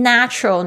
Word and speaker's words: natural: Number 8, natural, natural N-A-T-U-R-A-L 自然的。那我这边想要提点一下它的名词是 nature， nature 它的发音呢就跟它有点不太一样，前面 natural 你会发现那个 A 变成蝴蝶音，你的舌头要向下压 natural，